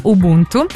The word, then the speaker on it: Ubuntu: Si pronuncia Ubuntu,